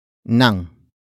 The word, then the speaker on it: nang: It is followed by nang.